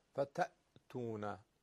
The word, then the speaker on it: فتأتون: فتأتون افواجا، فتأتون، همزه تیز بدون قلقله، محکم همیشه ساکن تلفظ میشه.